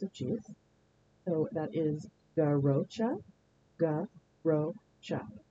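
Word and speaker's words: Garrotxa: It comes from Catalonian Spanish, and the pronunciation is garrocha, garrocha.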